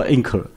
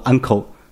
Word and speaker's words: encore: 就是如果你有音乐家演奏了一曲之后，大家都很喜欢鼓掌之余，他谢幕的时候鼓掌之余，可能有就喊出 uncle，意思就是说再来一曲，不只是音乐，在很多方面都可以。但这个原来是法国人用的，这个字是法语，它拼成 encore， E-N-C-O-R-E，如果英文一定读 anchor，可是他读作 uncle，那是法文的读法。